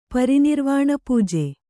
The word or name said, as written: ಪರಿನಿರ್ವಾಣಪೂಜೆ